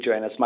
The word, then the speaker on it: Joannes: All my handles on social media are David Joannis, my full name.